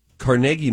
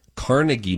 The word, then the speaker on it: Carnegie: Now this, Carnegie Mellon, or as Donna forces me to say it, Carnegie Mellon. – It's how they say it in New York, Carnegie Hall. Nobody says Carnegie Hall. – But I think in Pittsburgh, where Carnegie Mellon is, we say Carnegie.